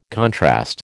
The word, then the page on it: contrast: American English